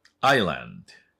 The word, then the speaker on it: island: island。 island。これ、島。これは大丈夫でしょうね。はい。この is って書いてね。 i。ね。イスランドじゃないですね。アイランドね。